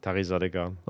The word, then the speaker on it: Taghizadegan: Rahim Taghizadegan, danke, dass du dir Zeit genommen hast. Habe ich den Namen richtig ausgesprochen? – Einigermaßen. – Kannst du es nochmal machen? – Taghizadegan.